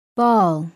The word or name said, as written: ball